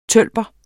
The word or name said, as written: tølper